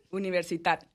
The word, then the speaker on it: Universitat: No se lee así, debe ser catalán esto. – No es. – ¿Cómo se pronuncia? – Universitat de Barcelona.